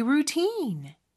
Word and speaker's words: routine: 루틴 이음절의 강세가 있고 모음 소리는 길게 빼주셔야 합니다. 질문이니까 끝을 올려줘야 되겠네요. What's your daily routine?